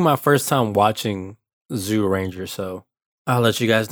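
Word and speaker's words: Zyuranger: And we're comparing those with the Japanese episodes from it's pronounced Zooranger, but it's spelled Zooranger, so I'm gonna say Zooranger.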